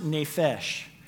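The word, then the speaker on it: nefesh (נפש): And then we have the second Hebrew word, and it's pronounced nefesh.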